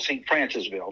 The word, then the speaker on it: St. Francisville: I think the best experience I ever had was at the St. Fran, let me make sure I say it right. It's on the east bank side of the river, past Baton Rouge, town called St. Francisville.